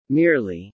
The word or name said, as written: merely